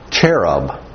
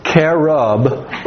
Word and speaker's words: cherub: But the pronunciation would be more with a K sound. There really isn't a ch sound in Hebrew. Okay, it's not there. So cherub or cherubim would really be our pronunciation of that particular word.